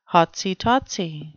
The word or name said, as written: hotsy-totsy